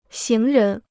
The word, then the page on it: 行人: xíng rén